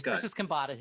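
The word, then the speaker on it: Persis Khambatta: Yes, Persis Kambada, who played Ilea.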